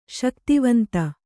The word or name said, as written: ಶಕ್ತಿವಂತ